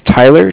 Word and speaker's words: Tyler: Yeah, my name is Tyler, T-Y-L-E-R. – All right. – And I'm calling with Cardiff. It's spelled C-A-R-D-I-F-F, as in Frank Frank.